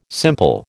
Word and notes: simple: American English

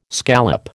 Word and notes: scallop: American English

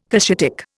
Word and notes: Cushitic: Indian

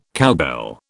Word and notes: cowbell: British English

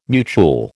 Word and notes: mutuel: American English

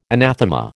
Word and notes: anathema: American English

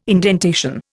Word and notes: indentation: Indian

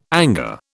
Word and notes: anger: British English

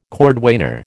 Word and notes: cordwainer: American English

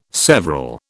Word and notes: several: British English

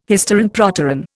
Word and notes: hysteron proteron: Indian

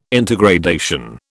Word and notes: intergradation: British English

